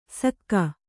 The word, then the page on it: ಸಕ್ಕ: Kannada